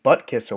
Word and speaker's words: Butkus: I think this guy's got a real good chance to win this one, is the Butkus Award. Am I saying that right? – Yes, you are.